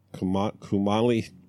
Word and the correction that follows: Kumail: I'm like, how do I pronounce his name? – Kumail Nanjiani.